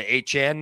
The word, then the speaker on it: Achane: De- Devin and we, I believe he wants to go by Achane now.